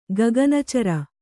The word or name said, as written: ಗಗನಚರ